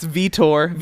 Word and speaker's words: Vitor: take out the C. – It's Vitor. – Vitor Belfort.